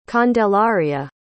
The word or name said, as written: candelaria